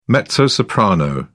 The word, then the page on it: mezzo-soprano: British English